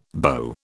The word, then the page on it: BO: British English